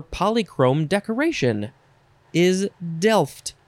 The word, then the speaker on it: delft: D-E-L-F-T, delft. How much do you pronounce the T? You just say delft, delft, delft.